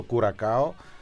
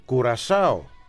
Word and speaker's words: Κουρασάο: Η Ευαγγελία, μια μικρή λεπτομέρεια στη συζήτηση, έναν εννοείται το κουρακάο, προφέρεται κουρασάο. Οι ντόπιοι το λένε κουρασάο, τους χαιρετισμούς μας.